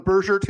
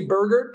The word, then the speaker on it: Burgert: And then Sunday, Ryan Burgert, and we're going soft G on Burgert here. It's not Burgert. – According to Jeff Sanders, most people around the team will still say Burgert, but that doesn't mean anything.